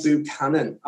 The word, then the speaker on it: Buchanan: We're getting into, is Neil Buchanan Banksy? – Ooh. – Well, it's been suggested in the news that Art Attack's Neil Buchanan, am I saying that right? – Buchanan.